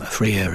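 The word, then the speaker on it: Frere: So Freer is, and he's called Freer. It's spelt Freer as brother in French, Frère.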